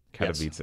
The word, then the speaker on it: Katowice: And we're back with Bloomberg Environment, who just got back from Katowice, where he was... – Katowice. – Sorry, it rhymes with pizza. Katowice.